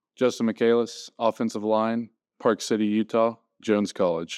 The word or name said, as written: Justin Michaelis